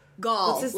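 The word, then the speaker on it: Ghaul: Gaul? No. Gaul? Gaul? Gaul? – Gaul. Like, you gall. Have the gall to do this.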